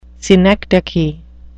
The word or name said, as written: synecdoche